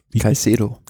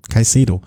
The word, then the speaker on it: Caicedo: Also, Almeida im rosa Trikot vor, ich kann es immer noch nicht aussprechen, Jonathan Clever, Caicedo. – Caicedo. Wie kann ich mir das merken? Caicedo. Vor Bilbao,